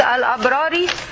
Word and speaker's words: الأبرار: Okay, I want you to know how to pronounce the word al-abrari. Okay, this is al. Okay, al, then ab. There is a sukun here. You divide it into 3 words: al, ab, rari. Okay, it is al, ab, rari.